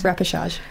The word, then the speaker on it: repechage: Repechage.